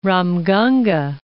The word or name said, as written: Ramganga